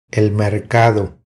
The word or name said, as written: El mercado